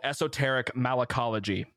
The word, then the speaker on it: Esoteric Malacology: The album is called Esoteric Malacology. I believe I pronounced that right. So yeah, that's correct.